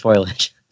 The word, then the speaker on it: foliage: I have to say that it's true. It is pronounced foliage.